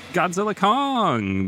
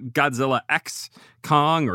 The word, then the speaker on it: Godzilla x Kong: The X in the title is silent for some reason. It's not Godzilla X Kong or Godzilla times Kong. It's just Godzilla Kong, the new empire.